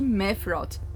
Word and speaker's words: Mephiroth: Então cola com a gente, porque neste CryptaCast vamos falar sobre o universo de Mephiroth. Tô pronunciando certo, gente? – Tá. – Tá certinho.